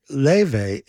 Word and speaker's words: Laufey: And if you look for any of her music, Leve, you have to understand that the spelling is totally different from how she pronounces it. If you're looking for it, Leve is spelled L-A-U-F-E-Y.